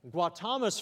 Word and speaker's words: Gautama: Did I say it right? – Gautama. – Yeah, see what he said.